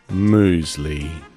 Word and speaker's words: Moosley: Back for another Thursday morning sound of Moosley. Moosley, M-O-O-S-L-E-Y, Moosley.